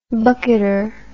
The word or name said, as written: bucketer